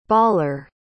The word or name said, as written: baller